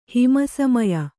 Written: ಹಿಮಸಮಯ

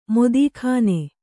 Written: ಮೋದೀಖಾನೆ

